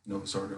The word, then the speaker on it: Novus Ordo: Novus Ordo, yeah. You have to say it in Latin, though. It's not the New Order Mass. That sounds creepy.